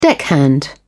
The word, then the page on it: deckhand: British English